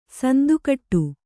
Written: ಸಂದುಕಟ್ಟು

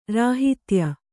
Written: ರಾಹಿತ್ಯ

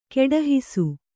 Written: ಕಡೆಹಾಸು

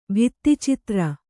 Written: ಭಿತ್ತಿಚಿತ್ರ

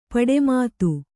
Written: ಪಟುಮತಿ

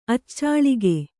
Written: ಅಚ್ಚಾಳಿಕೆ

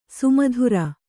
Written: ಸಮುದ್ರ

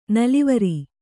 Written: ನಲವರಿ

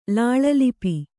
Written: ಲಾಳಲಿಪಿ

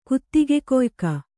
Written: ಕುತ್ತಿಗೆಗೊಯ್ಕ

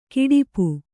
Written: ಘಾಟುಪ್ಪು

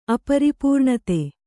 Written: ಅಪರಿಪೂರ್ಣತೆ

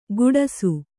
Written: ಗುಡಸು

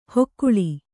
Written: ಹೊಕ್ಕುಳಿ

